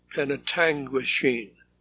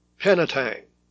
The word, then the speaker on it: Penetanguishene: It's sort of like this. Penetanguishene, and it rhymes with machine. Although he spells it differently phonetically, Vern Nicholson in Toronto says it's pronounced Penetang machine, often shortened by locals to Penetang.